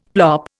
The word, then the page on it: plop: Indian